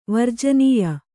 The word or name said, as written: ವರ್ಜನೀಯ